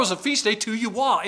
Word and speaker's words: Yahweh: Mispronunciation of some people is Jehovah. In Hebrew, there is no J sound. It's Yahweh.